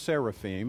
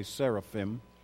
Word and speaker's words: seraphim: Really, it's pronounced seraphim. Most of us will say seraphim. And that's okay if you want to say seraphim.